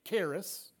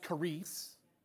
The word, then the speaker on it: charis: And then, if you're from Texas, it's charis. You familiar with charis? Well, in the Greek, it's pronounced charis.